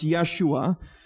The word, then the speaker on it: Yeshua: In Hebrew, it's pronounced Yeshua. And in English, we pronounce that Joshua.